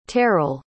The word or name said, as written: terrall